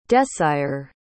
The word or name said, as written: desyre